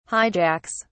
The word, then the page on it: hijacks: British English